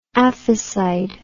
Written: aphicide

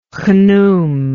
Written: Khnum

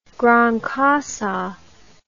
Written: gran cassa

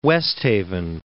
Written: West Haven

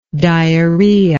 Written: diarrhoetic

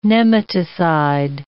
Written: nematocide

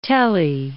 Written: tele

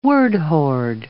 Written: word-hoard

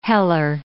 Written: heller

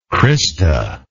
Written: crista

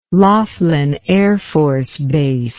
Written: Laughlin Air Force Base

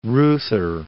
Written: Reuther

